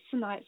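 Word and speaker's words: Senait: It's Sunite.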